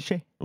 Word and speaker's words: Shai: Et donc on dit Shai. – On dit Shai. – On dit Shai. Et bah du coup Shai, le moment où il m'a impressionné, c'est dans son duel avec Dylan Brooks.